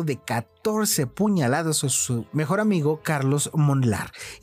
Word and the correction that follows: Molnar: Güey, ¿Cómo? pronuncias una L después de una N? Una L. Mm, sí, Molnar. Molnar. Molnar.